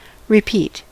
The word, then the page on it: repeat: /ɹɪˈpiːt/